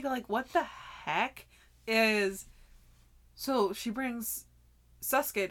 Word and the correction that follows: Sosuke: to, am I saying his name right? – Sosuke to school.